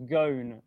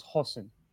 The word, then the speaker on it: Ghosn: Yeah, I mean, so he's mostly been going by Ghosn. Carlos Ghosn rhymes with cone for his entire career. But in Lebanon, they would say Carlos Hossein.